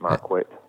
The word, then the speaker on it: Marquet: Yeah, well, that's how I pronounce it, Marquet.